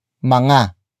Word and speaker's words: mga: But before that, we have manga, manga, manga, manga is spelled as M, G, A.